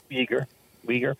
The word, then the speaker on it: Uyghur: So the United States is sanctioning Chinese officials over the Uyghur, am I pronouncing that right, human rights abuses? – Yes, Uyghur. Yes, this is interesting.